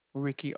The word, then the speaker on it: Rickey: Ricky, R-I-C-K-E-Y. – R-I-C-K-E-Y.